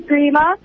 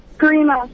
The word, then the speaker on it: Garima: Hi, my name is Grima, and I think the answer is water polo. – What's your name? – Grima. – How do you spell it? – My G-A-R-I-M-A.